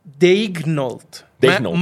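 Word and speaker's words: Daigneault: Ah, mira, dice pronounced Dignold.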